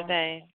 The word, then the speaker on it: Notre Dame: Notre Dame.